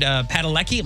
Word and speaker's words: Padalecki: Finally, Supernatural may be over, but fans of Jared Padalecki, am I saying his name right? – Yeah.